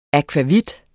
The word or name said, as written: akvavit